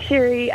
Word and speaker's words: Scerri: It's Beck Sherry Photography, but I've got a foreign last name, so maybe I should rebrand it because it's spelt S-C-E-R-R-I.